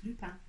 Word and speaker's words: Lupin: So it's pronounced Lupin.